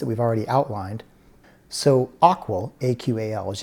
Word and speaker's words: AQAL: And we abbreviate that AQAL. We pronounce it the AQUAL model.